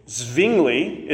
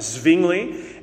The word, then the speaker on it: Zwingli: Zwingli is one way to pronounce this. The W in this era and in these European cities will often have a V sound.